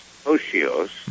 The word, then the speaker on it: otiose: O-T-I-O-S-E, pronounced Oshios.